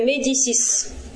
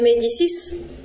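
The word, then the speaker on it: Médicis: Сафам Мари де Медисис. Французы это произносят семейство своеобразно. Так, Филипп Труа. Это Медичи, они так их произносят. Мари де Медисис.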